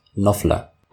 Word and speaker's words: Knopfler: Wie spricht man ihn aus? – Knopfler. – Mark Knopfler? – Sicher? Ja.